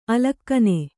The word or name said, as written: ಅಲಕ್ಕನೆ